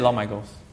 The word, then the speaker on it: Lorne Michaels: You said Lorne Michaels.